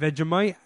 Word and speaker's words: Vegemite: I've never had Vegemite.